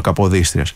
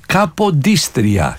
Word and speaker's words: Καποδίστρια: Τι κάνει λοιπόν ο Καποδίστριας? Έρχεται σε μια Ελλάδα. – Να τον προφέρουμε έτσι όπως μου αρέσει που τον γράφετε, που τον σημειώνετε. Είναι ο Κόντε Τζιοβάνι Αντώνιο Καποδίστρια. Καποδίστρια.